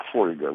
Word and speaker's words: фольга: В словарях, даже в словарях там, моей молодости, например, существовало слово «фольга», вот с этим ударением, да? Но я не помню, чтобы кто-нибудь реально так произносил. Вот, оказывается, нормой является «фольга». Вы скажете «фольга»?